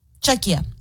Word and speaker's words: Czechia: Oh, Czechia.